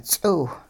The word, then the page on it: ts'iw: hupa